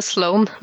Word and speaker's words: Sloan: Slow and Digital Ja, den heter Sloan, men uh, man kan kanskje uttale det som man vil.